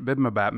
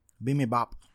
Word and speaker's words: bibimbap: So we learned what bibimbap means. What the fuck? Clearly I didn't learn because I just mispronounced it again. – Bibimbap.